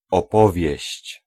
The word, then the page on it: histoire: /is.twaʁ/